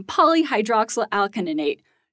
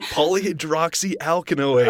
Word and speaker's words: Polyhydroxyalkanoate: easier-to-recycle alternative made from polyhydroxyl alkaninate. You gave me all the hard words. Oh my gosh, is this because I'm new? I don't even know if I pronounced that correctly. – Polyhydroxyalkanoate.